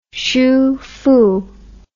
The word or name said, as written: sufu